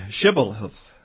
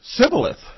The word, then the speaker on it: Shibboleth: And the individual, this was a test, a password, and he would say Sibboleth. He could not pronounce the H, for he couldn't pronounce it right, as the scripture says here.